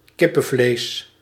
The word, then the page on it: poulet: France; [pu.lɛ]